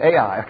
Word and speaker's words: Ai: Now, see the word Ai? That's how you pronounce it, Ai, okay? Just in case you didn't get it. And they're going to go to the city of Ai.